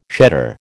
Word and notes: shedder: American English

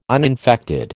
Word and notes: uninfected: American English

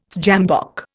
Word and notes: sjambok: Indian

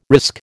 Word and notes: risk: American English

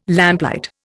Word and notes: lamplight: Indian

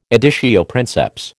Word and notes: editio princeps: American English

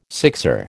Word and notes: sixer: American English

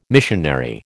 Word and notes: missionary: American English

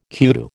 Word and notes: kudu: American English

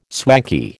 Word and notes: swanky: American English

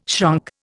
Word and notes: shrunk: Indian

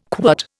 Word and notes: quirt: Indian

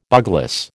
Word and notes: bugloss: American English

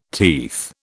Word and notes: teeth: British English

